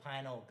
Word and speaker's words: pineal: Pineal gland is blinking. Right? – Hey, how do you pronounce pineal? – I say pineal gland.